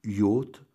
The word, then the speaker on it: yacht: En tant que navire de guerre, il vient du néerlandais jach, apparenté avec l'allemand Jagen, alors Jagen, J-A-G-E-N, chassé, qui voulait dire chassé. Attesté en français en 1570, pardon, alors orthographié Yach, il sera un temps prononcé Yak, et il semble que l'on prit l'habitude de le prononcer Yacht en pensant que le mot était anglais.